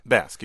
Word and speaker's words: Basque: My first language is it pronounced Basque? – Basque, yeah.